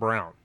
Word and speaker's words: Braun: So the Americans brought over from Nazi Germany scientist named Werner von, and I've heard Braun and I've heard Brown. I've heard it pronounced two different ways. We're going to go with Braun because that's the way it's German. It's A-U-N probably.